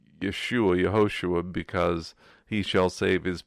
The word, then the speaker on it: Yehoshua: Nikedot, it would indicate the vowel, but so it's Yehoshua. So Yehoshua, which means Yah or Yahweh, if you're going to pronounce the name of God, that God is salvation.